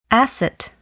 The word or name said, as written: asset